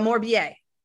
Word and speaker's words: Morbier: La Morbia. No. Yeah. No. La Morbier. La Morbier. Yes. Morbier.